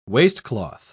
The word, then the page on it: waistcloth: British English